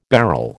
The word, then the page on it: bharal: American English